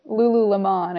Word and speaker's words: Lululemon: Lululemon, I think, is actually how you pronounce it, but good try.